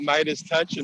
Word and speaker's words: MeidasTouch: Well, in addition to checking out Midas Touch and our various videos there, which is spelled MEIDASTOUCH, and you can find us at Midas Touch on Twitter.